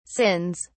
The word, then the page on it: sins: British English